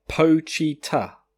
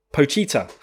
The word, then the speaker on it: Pochita: Um, he made he made a comment on how I pronounce the name. Uh, the dog is called uh, Pochita. Pochita. Pochita. That's a great name.